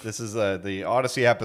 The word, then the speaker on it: Audacy: What is it? Yeah. Odyssey? No, it's Odyssey. – Yeah. – Oh, how do I spell that? A-U-D-A-C-Y. Good.